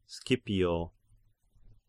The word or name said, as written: Scipio